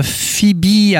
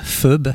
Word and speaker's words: Phoebe: Je l'avais prononcé, moi, à l'allemande, Phoebe. Ça s'écrit P-H-O-E-B-E. Et en fait, ça se prononce Phoebe.